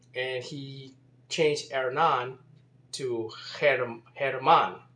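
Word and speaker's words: Germán: And by the way, what happens, that name German is really more like Herman. And what happens is in Spanish, the G, just like the H is silent in Spanish, the G sounds like the English H. It has that H sound.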